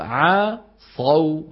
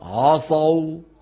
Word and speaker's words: عصوا: الصواب عصوا الغلط عصوا، شايفين الغلط؟ نطقها شبه الهمزة، نطقها شبه الهمزة.